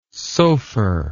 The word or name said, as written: sophar